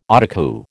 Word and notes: Autocue: American English

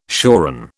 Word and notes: shoran: British English

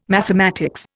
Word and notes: mathematics: Indian

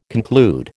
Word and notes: conclude: American English

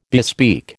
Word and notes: bespeak: American English

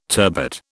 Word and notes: turbot: British English

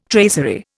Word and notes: tracery: Indian

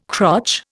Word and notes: crotch: Indian

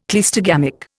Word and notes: cleistogamic: Indian

